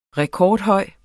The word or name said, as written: rekordhøj